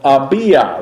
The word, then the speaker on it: Abijah: His division is called Abija. That's how it's pronounced, A-B-I-J-A-H. The J-A-H is short for Yahweh, and it literally means, Abija means, Yahweh is my father.